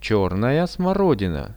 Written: chernaja smorodina

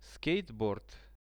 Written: skateboard

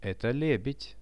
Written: eto lebed

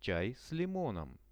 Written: chai s limonom

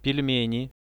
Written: pelmeni

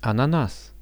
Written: ananas

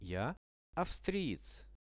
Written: ja avstriec

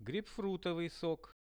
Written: geipfrutovyi sok

